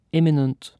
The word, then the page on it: imminent: British English